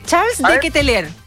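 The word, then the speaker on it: Charles De Ketelaere: Sí, mira, dije Charles de Ketelaer. – Perfecto. Bien. Extraordinaria tu pronunciación del belga.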